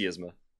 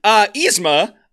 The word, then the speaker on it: Yzma: It's Yzma. It's God damn, Yzma. – It's Yzma. – Yzma. – They're pronouncing it wrong in the movie. It's Yzma. – No, it's Yzma.